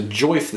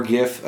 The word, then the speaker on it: GIF: There's a joif that's going around recently. – A joif? – Yes, I refuse to pronounce it as jif or gif. I pronounce it incredibly wrong just to irritate everyone.